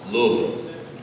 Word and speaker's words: Louisville: Louisville, I guess, is the correct way to pronounce it.